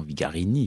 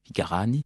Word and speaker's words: Vigarani: Très souvent, son nom est écorché en Vigarini. Évidemment, c'est une erreur. C'est Vigarani. C'est-à-dire qu'il y a un accent normalement tonique qui vient s'installer sur la 3e syllabe.